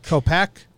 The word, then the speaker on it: Kopech: Kopich, is that how you say it? I think it's Kopech. Kopech. Yeah.